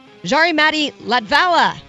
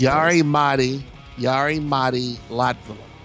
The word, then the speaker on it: Jari-Matti Latvala: Jari-Matti Latvala. I'm saying that wrong, I'm sure. Winning Rally Sweden, correct? – Say it correctly. – Yes. – Jari-Matti Latvala. – Latvala. Okay, so I pronounced the...